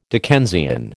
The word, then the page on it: Dickensian: American English